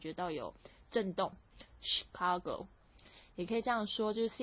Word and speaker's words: Chicago: 是 Chicago。好，那但是呢，在这芝加哥这个本地的地方呢，也有人会讲 Chicago，所以这两个发音都可以的。